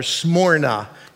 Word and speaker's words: Smyrna: And the Greek word for myrrh is Smyrna, or Smyrna is pronounced properly.